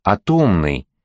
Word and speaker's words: атомный: В слове «атомный» Белый ставит ударение на втором слоге, что, вообще-то говоря, правильнее, поскольку «атомос» по-гречески означает «неделимый», а ударению логично приходится на корень слова, не на приставку.